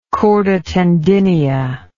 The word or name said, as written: chorda tendinea